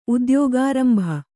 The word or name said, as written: ಉದ್ಯೋಗಾರಂಭ